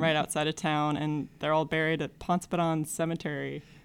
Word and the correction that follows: Pontoppidan: So I think it's Pontoppidan locally. – Pontoppidan.